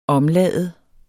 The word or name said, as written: omlade